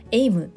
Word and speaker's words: AIM: コミュニケーションのフレームワークエイムをご存知でしょうか大変お恥ずかしい話なのですが最初私読み方も分からず AIM とか IM とか読んでいたんですけれどもちゃんと読むと AIM というふうに発音をするそうです